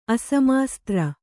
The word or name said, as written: ಅಸಮಾಸ್ತ್ರ